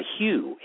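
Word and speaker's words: HU: But the coolest one I always liked, and it's universal with Eckhart, and it's called the Hugh, H-U. It's pronounced like the name Hugh, like Hugh Grant, or H-U-E, like Hugh, like the pastel.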